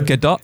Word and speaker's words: Gadot: Her name is Gadot, though, isn't it? That's how you pronounce it, apparently.